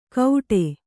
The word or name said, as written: ಕೌಡೆ